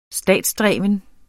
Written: statsdreven

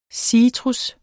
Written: citrus

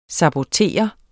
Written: sabotere

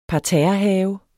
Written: parterrehave

